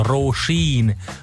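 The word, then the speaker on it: Róisín: írnek, hogy sokan Roizinnek mondják, vagy próbálják kiejteni R-O-I-S-I-N, és ugye a második I az hosszú, meg az O is hosszú, hát ír, az írben vannak ilyenek. Na most az ő nevének a helyes kiejtése az, hogy Roisin, Roisin Murphy,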